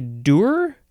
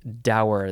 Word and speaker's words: dour: The next word is, I guess you can say dur. Let's see. Let's check the pronunciation. Dur or dour. I like dour. That's how I've always heard it and said it. It's D-O-U-R.